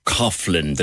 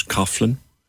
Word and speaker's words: Coughlan: I was talking about people whose name was C O U G H L A N, pronouncing it as Coughlin to be posh.